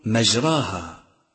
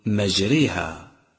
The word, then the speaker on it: مجراها: ثانيا الألف الممالة، وهي ألف متولدة من الألف والياء، مثل مجريها، ولا ألف ممالة في قراءة حفص غير هذه الألف، وإياك أن تخطئ في نطقها فتقول مجراها، بل انتبه إلى إمالتها فقل مجريها.